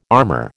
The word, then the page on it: armor: American English